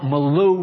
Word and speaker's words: milieu: Does everybody know what milieu means? It's properly pronounced milieu, but I know that sounds affected, so we'll call it the prophetic milieu.